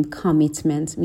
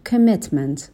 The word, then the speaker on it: commitment: به تلفظ کلمه commitment دقت کنین، در ابتدای کلمه صدای شوا رو داریم، صدای آ نداریم. نمیگیم commitment، میگیم commitment.